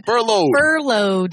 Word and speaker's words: furlough: Furlough. – Like a load. – Oh, I see. – Yeah. – But furlough. With fur on it.